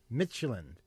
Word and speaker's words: Midtjylland: Michelin. – Okay, the Danish team. MIDTJYLLAND. That's why I have trouble pronouncing it, because that's how it's spelled.